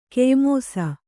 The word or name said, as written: ಕೆಯ್ಮೋಸ